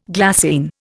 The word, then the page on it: glassine: Indian